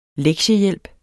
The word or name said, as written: lektiehjælp